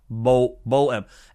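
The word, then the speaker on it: Bohm: And am I saying his name right? Because I always flub that one. Baum. Baum. Alec Baum. Bo-M. Alec Baum. Alec Baum, not Baum.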